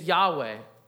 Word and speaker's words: Yahweh: The actual name of God, it's pronounced YHWH. Now, we stick consonants in English between those letters, so we put an A and an E, and it's Yahweh.